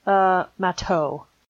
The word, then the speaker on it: Matou: Spell it out for me. – M-A-T-O-U? – Matau. – Matau. Yeah, that sounds about right.